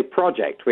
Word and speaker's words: project: How would you pronounce the word project? Is it project or project? – Well, I always say project, which I think is the way most British people would say.